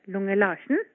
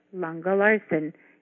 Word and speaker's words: Lunga-Larsen: You might be interested to know that the way you would pronounce it in Norwegian is by saying Lise Lunga-Larsen. Remember, Lise Lunga-Larsen in Norwegian, or Lisa Lunga-Larsen in English.